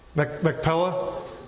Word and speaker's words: Machpelah: So the field of Ephron, and does anybody know how to say this? Help me out here. You know how to say this? Machpelah, that's good.